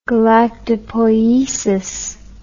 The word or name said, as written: galactopoiesis